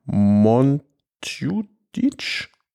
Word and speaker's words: Montjuïc: Vereinfacht würde er sagen Montjuïc. Schön ausgesprochen.